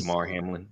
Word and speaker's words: Damar Hamlin: Hold on, we can kind of pivot a little bit so I was looking at I make sure I'm saying his name right, Damar Hamlin. Am I correct? – Yes. – Damar Hamlin, looking at his name.